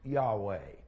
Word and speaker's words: Yahweh: We think that it is pronounced Yahweh. And I say we think because we don't know for sure.